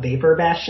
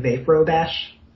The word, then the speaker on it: Vaprobash: It's actually really hard to pronounce. I almost wanted to call it Vapor Bash instead of Vapro Bash.